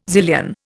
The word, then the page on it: zillion: Indian